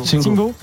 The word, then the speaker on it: Singo: Singo, Singo, la prononciation Singo. – Singo. comme on dit Conan et pas Conan.